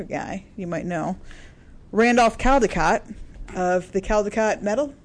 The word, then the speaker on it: Caldecott: The Caldecott Medal is awarded to the artist of the most distinguished American picture book for children from the previous year, since 1937.